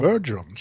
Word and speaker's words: Bergeron: Bergeron, säger man. – Ja, nu är det mitt franska. – Det kommer ett nytt uttal.